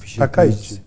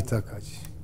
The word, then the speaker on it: Takaichi: Askeri manada Japonya'yı yeniden zaten bu kadıncağız neyse işte o başbakanları bunun Takaichi. – Evet siz iyi telaffuz ediyorsunuz. – Hatta Sanae Takaichi. – Sanae Takaichi.